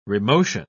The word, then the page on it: remotion: British English